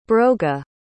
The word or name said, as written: broga